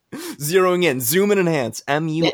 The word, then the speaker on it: Muskegon: So okay, how many people are in Muskega? – Muskegon. – Did I say it right? – Muskegon.